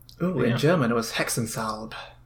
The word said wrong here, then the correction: Hexensalbe: Cause I probably said that wrong. – It would be Hexensalbe, probably.